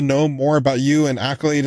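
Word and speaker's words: Akylade: It's A-K-Y-L-A-D-E. And it's pronounced Accolade, like you got an accolade because you did great things.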